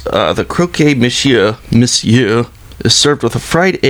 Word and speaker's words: monsieur: I think it's monsieur. – No, it's pronounced monsieur.